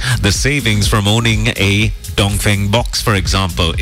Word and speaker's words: Dongfeng: If you haven't heard about the Dongfeng series that's available at Euro Motors, it's actually pronounced Dongfeng. – Dongfeng. – Correct. – Yep. – But unfortunately, everyone's got used to saying Dongfeng.